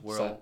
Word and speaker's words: Worrell: But before we get into that, welcome everyone to the Aged Out podcast with Mike Fantini and Evan Worrell. – That's a weird way to say your last name. – Yeah, well, my last name's Worrell. – So I had to do something different with it.